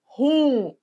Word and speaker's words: ruim: As pessoas que falam ruim, elas falam assim porque elas acham que a sílaba tônica é no ruim, né? Mas é ruim. Ruim, não ruim. Nada de falar por aí. É ruim, hein? É ruim. tudo bem? É o IM.